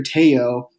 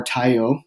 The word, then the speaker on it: Tayo: So the main character, Teo, or Tayo, depending on how you pronounce it, is an indigenous man of the Pueblo peoples.